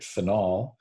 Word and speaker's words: phenol: And the name phenol, which is also important to note, because sometimes in my pronunciation, I want to hit phenol, which is the aromatic alcohol, and a phenyl, P-H-E-N-Y-L, which is a substituent, an aromatic ring system as a substituent is a phenyl group.